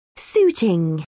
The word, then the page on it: suiting: British English